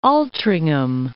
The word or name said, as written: Altrincham